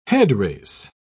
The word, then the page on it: headrace: British English